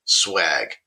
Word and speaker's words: schwag: Yes, according to Merriam-Webster, the freebie swag, sometimes also spelled S-C-H-W-A-G, dates back to the 1960s and was described to use promotional items. So it is spelled the other way, but the pronunciation is swag.